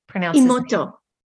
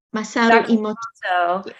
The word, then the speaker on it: Masaru Emoto: So and by the way, he was good friends with Masaru Emoto. Okay, gentleman. – Yes. Yeah, so who started the water? And I can't pronounce his Emoto. Masaru Emoto.